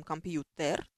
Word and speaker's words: компьютер: Ну, сравните, например, слово «компьютер» мы берем, мы говорим «компьютер», то есть «т» не смягчаем, а пишем при этом на бумажке буковку «е». Почему такое произношение, не очень понятно, но так вот должно быть.